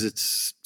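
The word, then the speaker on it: ¡Ay!: Uh, and I believe it's just pronounced I. It's got an inverted uh, exclamation point followed by an AY followed by an exclamation point.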